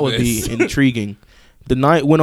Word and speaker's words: DeKnight: In a new interview with Collider, Pacific Rim Uprising director Stephen DeKnight, did I say that right? – Yes, sir.